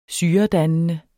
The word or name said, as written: syredannende